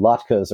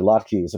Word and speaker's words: latkes: And then some latkes or latkes, apparently they're both correct pronunciations, made from wild parsnip with some wild onion and a few sauces, mostly made with stuff that's in season right now.